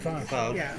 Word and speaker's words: falsch: Yeah, there are some Falsch. – Falsch. – Falsch. – Yeah, pronounce it right.